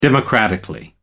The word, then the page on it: democratically: American English